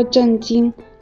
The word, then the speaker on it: 震惊: 所以在说的时候，大家一定要注意区分声调。 – 啊，遇到问题要保持镇静，不要震惊。